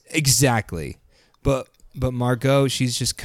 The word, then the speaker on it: Margot: I think that is not how you pronounce her name. – Yeah, you put a crazy emphasis on the Mar. – Margot? – Yeah, it's not. It's not. – How do you pronounce it? – Margot. – Oh, Margot.